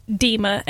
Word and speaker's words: Dima: But for example, my question, my name is usually just Dima, and I don't find it hard for yeah.